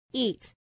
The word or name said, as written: eat